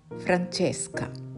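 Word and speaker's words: Francesca: フランチェスカ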